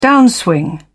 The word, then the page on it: downswing: British English